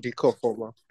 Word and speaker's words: Dika Ofoma: Dika Ofoma.